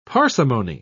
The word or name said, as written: parsimony